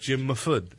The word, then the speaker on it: Mahfood: We're going to also have Jim Mafood. Going to be there. Love saying that name. – I hope that's correct. – That is correct. I Actually, I've heard it pronounced. – Jim Mahfood.